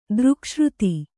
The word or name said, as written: ದೃಕ್ಶ್ರುತಿ